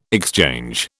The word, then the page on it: exchange: British English